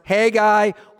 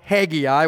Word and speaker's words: Haggai: Now, it can be pronounced Haggai or Haggai, whatever is your preference.